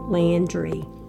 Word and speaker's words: Landry: It's spelled S like Sam, U, D like David, I-E, Landry. L-A-N-D-R-Y.